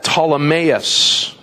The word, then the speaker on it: Ptolemais: When we had finished the voyage from Tyre, we arrived at Ptolemais," the P is silent, by the way, "and we greeted the brothers and stayed with them for 1 day.